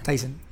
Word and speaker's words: Tizen: El sistema operativo que trae este televisor sigue siendo, ¿cómo se dice? Tizen o Tyson? Tizen Sigue siendo Tizen Sí.